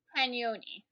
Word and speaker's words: Pagnoni: I didn't want to butcher your last name, but it's Rena Pagoni, is that right? – Pagnoni. You're close.